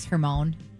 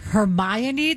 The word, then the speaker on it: Hermione: Can I tell you, when I read the Harry Potter books, I always thought it was Hermione, because of how it's spelled. And then the movies came out, and I was like, Hermione, this many years, I read all these books.